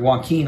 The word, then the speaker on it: Joaquin: And then when I remembered Joaquin, I couldn't remember how to pronounce it. – Okay. – Joaquin.